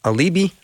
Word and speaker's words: Aleibi: And now Ben's still here with me, but we're going to bring on Aleibi Santana, who was a sponsored child himself, who is now in his early 20s with his own family.